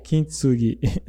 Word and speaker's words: Kintsugi: Né, como a filosofia do Kintsugi. É, é. Falei certo? – Kintsugi. É, Kintsugi.